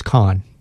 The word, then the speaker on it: Kahan: Noah Kahan, Tedeschi Trucks Band. – I think it's pronounced Kahn, by the way. Noah Kahn.